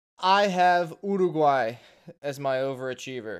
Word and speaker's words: Uruguay: Also, no one can say their name right. It's Uruguay. There's no "ur" in that.